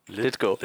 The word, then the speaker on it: Lithgow: Was ich auf jeden Fall richtig cool fand, waren so äh, John Leito oder so. Also der, äh, ne, wie, war, wie wurde ausgesprochen? Litgo. Litgo.